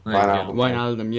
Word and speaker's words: Wijnaldum: Wijnaldum, yeah, there you go.